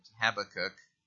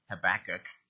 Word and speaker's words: Habakkuk: and Habakkuk 3, verse 19, or as Americans say, Habakkuk.